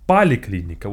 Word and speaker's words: поликлиника: In fact, it's pronounced поликлиника with the A.